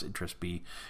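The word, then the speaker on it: Itras By: Uh, if you don't know that game, it's uh, spelled I T R A S BY. So BY is the second word, and I believe it's pronounced interest B.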